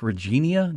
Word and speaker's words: Regina: I think it's Regina, but it says here that the way they pronounce it, it rhymes with you know what.